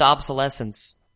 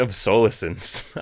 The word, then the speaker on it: obsolescence: How do you pronounce it? – Planned obsolescence. – Why am I pronouncing it obsolescence? I think I've only ever read it.